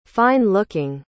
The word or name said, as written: fine-looking